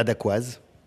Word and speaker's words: Dacquoise: He makes this dessert, and when I met with him, I asked him how to pronounce it. – La Dacquoise. – Dacquoise. Okay.